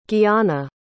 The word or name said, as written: guiana